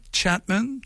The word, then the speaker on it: Chatman: Et l'air de rien, parce que j'aime à le dire quand même, tu sais, moi, Paul Chapman, tu vois, ça sonne la même affaire, mais moi, mon nom de famille, s'écrit C-H-A-T-M-A-N, et elle, Tracy Chapman, faut bien le prononcer, là. C-H-A-P-M-A-N.